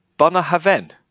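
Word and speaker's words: Bunnahabhain: It tells you how to pronounce it. Bunahaven. – Bunahaven. Nice. – Bunahaven. Not Mujahideen.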